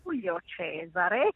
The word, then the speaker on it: Giulio Cesare: Che meraviglia, sono ancora qui tutta, come si può dire, inzuppata nelle arie di Handel, nelle arie di Giulio Cesare, che si deve pronunciare assolutamente con gli accenti all'italiana, mi spiace, non è Giulio, come dicevano qua ieri sera, perché è tutto in italiano e particolarmente stupenda, veramente uno dei grandi capolavori che si riascolterebbero all'infinito.